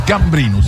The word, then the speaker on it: Gambrinus: E hai portato al Gambrinus, ma stai scherzando? – No, Gambrinus non si dice Gambrinus. – Vabbè, l'accento è Gambrinus.